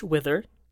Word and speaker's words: Wither: Harris Big Dash Wither. Yes. – Okay. Wither spelled like? – Like Bill Withers.